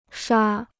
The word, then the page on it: 刷: shuà